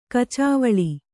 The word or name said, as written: ಕಚಾವಳಿ